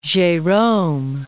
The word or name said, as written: Gerome